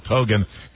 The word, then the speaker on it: Kogan: We interviewed him yesterday for about 20 minutes, and we had his name wrong. – It's Kagan, not Keegan. – Kogan. – No, Kogan. – No, it looks like Keegan. – We called him Phil Keegan, and he was actually Phil Kogan.